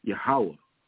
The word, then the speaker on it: Yahweh: It says Jehovah, but we know that the word J, or letter J, wasn't invented until the 1800s. And his name is probably pronounced in the Paleo-Hebrew, Yahweh.